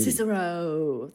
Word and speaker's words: Cicero: Cicero. That's in Chicago. That's how I knew how to pronounce it. If I've done it right, it's because of the musical Chicago.